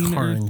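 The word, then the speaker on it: Karyn: Karen Kusama. Yeah. 2014. – Sorry, Karin Kusama. – Karin. – I didn't really pronounce that very well.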